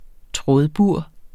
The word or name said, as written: trådbur